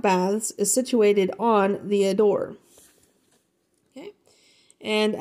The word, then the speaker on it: Adour: and that's not A-D-O-R-E, that's A-D-O-U-R, and to me, it looks like a dower, but it's saying it's pronounced ADOUR,